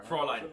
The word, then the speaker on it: Fräulein: Fräulein.